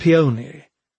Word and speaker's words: peony: Balcony, rhyming with pony, and peony, rhyming with pony.